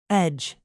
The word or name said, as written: edge